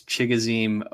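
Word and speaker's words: Chigoziem: Chai Gauzium. Okinkool. – I think it's also known as... – I do know how to pronounce that name. It's Chigozim Okonkwo.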